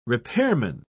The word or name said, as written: repairman